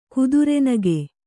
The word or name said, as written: ಕುದುರೆನಗೆ